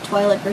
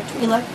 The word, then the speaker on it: Twi'lek: I'm playing Fina, Twilight or Twi'lek, depending on how you like to pronounce it.